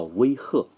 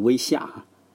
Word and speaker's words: 威吓: 而上级对下级呢，最常见的问题叫威吓，最好不要念成威吓。